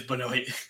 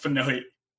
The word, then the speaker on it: Benoit: So is that how you say his name? – It is Benoit. – It's not Benoit? – No, it's Benoit.